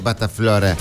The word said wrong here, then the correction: pasta frola: Frola. Frola, flora. Pasta frola, loco.